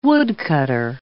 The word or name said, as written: woodcutter